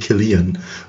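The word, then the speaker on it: Cillian: Äh, bei der Wikipedia er heißt tatsächlich Killian oder oh. Killian, wie auch immer man es gerne aussprechen möchte, aber mit einem K vorneweg.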